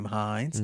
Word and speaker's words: Hines: Last name Hines.